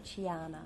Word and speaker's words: Chiana: Is it Kiana or Chiana? – Chiana.